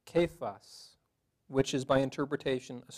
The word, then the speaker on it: Cephas: And I looked it up, it's pronounced Cephas.